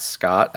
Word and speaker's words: Scott: Yep, at Scott, S-C-O-T-T, _Sidlow, S-I-D-L-O.